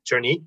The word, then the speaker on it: Jernite: Best way, just reach me out on Twitter at Jernit, my last name. On LinkedIn, Kareem Jernit. – That's J-E-R-N-I-T-E. – J-E-R-N-I-T-E. Kareem, K-A-R-I-M.